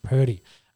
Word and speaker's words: Purdy: Brock Purdy, who is their rookie quarterback who will play.